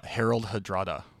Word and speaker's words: Harald Hardrada: Harald Hardrada. – Oh, very good. Harald Hardruler. Huge fan of that guy. Guess what? You've got 1 minute to teach me something about Harald Hardrada.